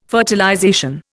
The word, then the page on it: fertilization: Indian